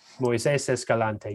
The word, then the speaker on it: Moises Escalante: One of the folks is named Moises Escalante. Is that right? – Yes, Moises Escalante.